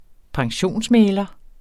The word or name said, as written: pensionsmægler